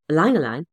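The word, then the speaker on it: ylang-ylang: Ylang ylang is actually pronounced E but spelled Y. Ylang ylang,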